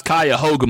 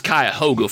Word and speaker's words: Cuyahoga: That's Cuyahoga, my friend. You better say that one right there, buddy. You are in America. You better pronounce these counties correct. It's Cuyahoga, friend.